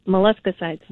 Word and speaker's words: molluscicides: Molluscicides. – Ooh. Yay. – And you pronounced it right, something I couldn't do on the first try.